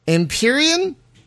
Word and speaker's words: Empyrean: So is it Empyrean? – Yep.